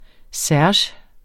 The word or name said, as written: serge